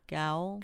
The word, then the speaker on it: gaol: At the old Marylebone, G-A-O-L. Marylebone Gaul? G-A-O-L? Yeah. How do you say that? – Gaul.